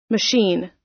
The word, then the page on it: machine: American English